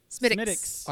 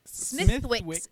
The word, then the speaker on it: Smithwick's: Which actually I find is that how you pronounce it? – Smittix. – I always thought it was like Smithwick's. – Smithwick's. No, yeah. Smittix.